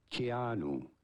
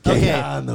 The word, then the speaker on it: Keanu: How do you go about pronouncing this name? – Say it. – Keanu. K-A-N-U. – All right. – Keanu. – Exactly like we thought. Keanu.